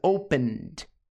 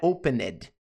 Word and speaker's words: opened: ED, pronúncia, opened, opened, não é opened. Esse ED do final tem som de t, opened, opened.